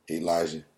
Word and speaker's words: Elisha: It's spelled Elisha, but they pronounce it Elijah.